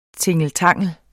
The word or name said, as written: tingeltangel